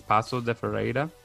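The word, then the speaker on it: Paços de Ferreira: You finished third with, I don't want to mispronounce it, but I think it's Paso de Ferreira. – Yeah, that's it. Right, yeah, it's good.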